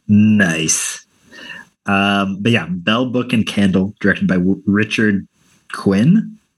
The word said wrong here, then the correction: Quine: Or Richard Quine. It's spelled Q-U-I-N-E.